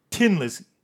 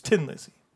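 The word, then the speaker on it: Thin Lizzy: Anyway, if you're from Ireland, you actually pronounce it Tin Lizzy. It's not thin, it's tin.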